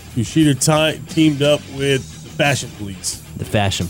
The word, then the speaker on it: Kushida: I learned how to say his name. Kushida.